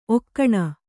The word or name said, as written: ಒಗುನಿ